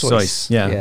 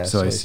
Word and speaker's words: Seuss: Although I will say that he always he actually insisted that Seuss was pronounced Seuss. – Seuss. Yeah, that's how it would be pronounced in German.